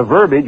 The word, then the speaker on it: verbiage: Unless you have discovered from a deeper source than just a philosophical dribble and the verbiage which rhymes with garbage,